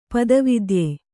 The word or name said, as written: ಪದವಿದ್ಯೆ